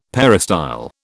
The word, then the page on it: peristyle: British English